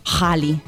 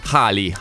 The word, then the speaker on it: Ghali: Casa mia, Gali su Radio Delta 1 oppure dovrei dire Hali. – Molto meglio perché l'ha spiegato proprio di recente in un'intervista, lui il suo nome in realtà con la pronuncia corretta sarebbe Hali. – In arabo.